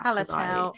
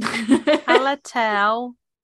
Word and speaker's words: Hallertau: Goldings and Helleratu. I can't pronounce that second half. – Hallertau, I cannot pronounce that. I can never pronounce that. That is a fucking hard one. – Hallertau, Mittelfrüh.